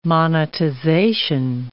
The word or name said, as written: monetization